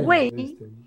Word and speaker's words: Güell: Porque yo se titula Parque Güell. Porque Güell, Güell, no es que sea inglés ni nada, es un apellido de aquí, de Cataluña.